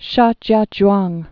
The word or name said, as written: Shijiazhuang